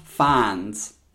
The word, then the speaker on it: Fiennes: Ralph Fiennes is how you say. I don't know why, but I looked up the correct way to say it.